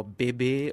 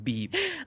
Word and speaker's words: bebe: Is it BB or Bebe or... I think it's, I call it Beeb. – Beeb? Because it's what's spelled as B-E-B-E? – Yeah. – Beeb. I would think it's B-B. – Yeah, because meme is M-E-M-E.